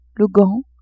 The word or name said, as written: gant